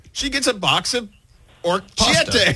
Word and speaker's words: orecchiette: Orecchiette. – Orecchiette.